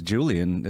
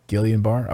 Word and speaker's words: Guillain-Barré: Am I saying that right? Guillain? I think I'm saying that right? – I think it's Julian, isn't it? – Julian? Yeah. Guillain-Barré? – No, Julian, because it's... You were saying it right the first time. I've heard it pronounced Guillain-Barré, like you were saying. – Guillain-Barré? Okay.